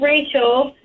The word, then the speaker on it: Rachel: Oh, so my name is Rachel, and everywhere I go, people misspell it with an extra A.